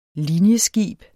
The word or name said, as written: linjeskib